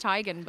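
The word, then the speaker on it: Teigen: So just if anyone doesn't know, Chrissy has said in an interview that actually her name is pronounced Tygan, but people have been pronouncing it Tegan for so long. She's just going with it.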